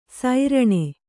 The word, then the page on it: ಸೈರಣೆ: sairaṇe